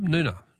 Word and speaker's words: Noongar: Noongar is pronounced with a silent G, is it? Oh, thanks, Barb. Noona, okay.